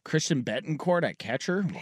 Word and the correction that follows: Bethancourt: It is pronounced Bethancourt.